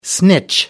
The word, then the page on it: snitch: American English